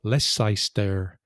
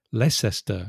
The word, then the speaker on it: Leicester: And an American say Tottenham because they also have that tendency, where a word they don't know sometimes in English, to pronounce every Leicester. So it's Leicester.